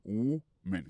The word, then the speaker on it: woman: Par contre, si nous remarquons ça, m'a parlé là, me dit et woman, doit parler de en fille au singulier. Prononciation c'est et woman, donc ou son ou.